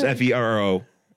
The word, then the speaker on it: Ferro: Faro, like Iron. – I was thinking like the grain. – No. Faro. – You know? – Oh, be a Faro Lad. That's his. – It's spelled Farro, isn't it? – No, it's Ferro. Ferro, kind of like.